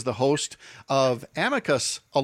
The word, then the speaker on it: amicus: I think the accepted is amicus, with the emphasis on the first syllable, as we often say.